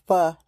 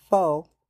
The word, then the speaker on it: pho: Hey, people, it's pronounced pho, not pho.